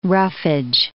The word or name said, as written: roughage